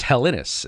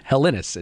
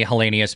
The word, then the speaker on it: Helenius: It's Helenis is how you say it. So let me start again. Helenis is how you say it.